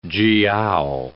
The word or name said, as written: jiao